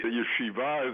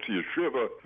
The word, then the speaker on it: yeshiva: Well, I attended the yeshiva, as it's pronounced in Israel. In America, it's yeshiva, the Yiddish way, through the ninth grade.